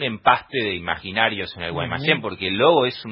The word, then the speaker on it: Guaymallén: Guaymallén nunca llegó la tilde. Se pronuncia Guaymallén, pero en el envoltorio no tiene la tilde.